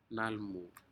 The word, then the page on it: nalmuh: Aneityum